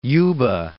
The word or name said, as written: Yuba City